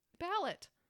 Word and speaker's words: ballot: So how do you say it? – Ballot. – Ballot. – I mean, if you're a regular American, you'll just say ballot. But it's not ballad.